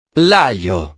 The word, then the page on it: l'aglio: italiano